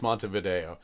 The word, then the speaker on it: Montevideo: I know it's Montevideo. – Oh, I have no idea. – Montevideo. – Oh, okay. All right. I went with like the very American pronunciation where I see video and I pronounce it like it's a tape.